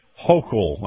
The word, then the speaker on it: Hochul: Now, let's go over to New York and let's talk about the Lieutenant Governor Kathy Hochul, I believe that's the way you say the name.